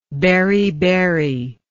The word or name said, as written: beriberic